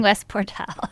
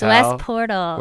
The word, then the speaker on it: West Portal: You kept saying West Portal. It's West Portal.